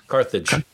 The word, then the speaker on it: Carthage: What's the pronunciation of that? Carthage. Carthage. Carthage. – Yeah. Yes.